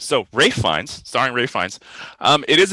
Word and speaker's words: Ralph Fiennes: It is Ralph Fiennes. You pronounce so it's spelled Ralph, but you say it Ralph.